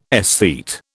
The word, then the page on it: aesthete: British English